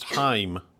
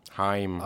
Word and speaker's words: Haim: Haim are pronounced Haim. – Haim.